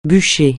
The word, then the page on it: bûcher: francais